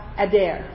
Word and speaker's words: addiyr: The Hebrew word for excellent or majesty is ADDIYR, pronounced Adair.